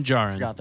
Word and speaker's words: Djarin: Din Djarin. – Din Djarin. – Yeah, D is silent.